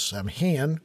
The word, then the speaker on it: Samhain: Some people prefer to pronounce it as Samhain, which are usually punk rockers from my high school days because it was Glenn Danzig's old band.